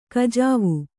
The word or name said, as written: ಕುಜುವೆ